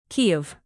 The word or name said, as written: kyiv